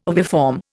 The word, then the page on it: oviform: Indian